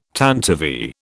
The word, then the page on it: tantivy: British English